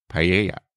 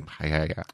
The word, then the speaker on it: paella: He was very annoyed with, and rightly so, with the way that we were mispronouncing paella. – Paella. – Paella. – Of course, in Australia, we say, with a very flat Australian accent, paella.